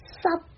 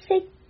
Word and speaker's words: 息: 如果闭上嘴巴，这个 C 的粤语就变成了 sup sup，跟湿透干湿的湿一模一样。正确的发音是息，叹息，叹息，叹息，休息，休息，休息，作息，作息，作息，息息相关，息息相关，息息相关。